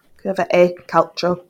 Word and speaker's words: Curva e Calcio: You've got to work on your pronunciation there. Come on. – Yeah, calcio. So almost. – Okay. Curva e Calcio. – Curva e Calcio.